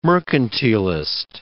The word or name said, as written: mercantilist